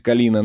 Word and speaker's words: Калина: «Чего ты хочешь, Калина?» Имя прозвучало странно с ударением на последнюю «а». «Я уже говорила тебе, мой дорогой Князев», — улыбнулась Калина, но в ее улыбке не было тепла.